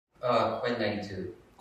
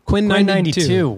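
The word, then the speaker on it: Quinn XCII: How do you say it? – Quinn92. – Quinn92. Wow.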